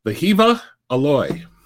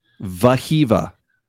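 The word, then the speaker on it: Wehiwa Aloy: and I was told in our comments on our mock draft how to pronounce this name. – Oh, okay. – Vahiva Aloy. – Vahiva. – Because apparently W's in that language are pronounced with a V.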